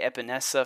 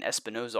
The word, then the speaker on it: Epenesa: I mean, those are two premier pass rushers there, and I may have butchered his name. I was saying Espinoza all freaking off season long, so at least I got close.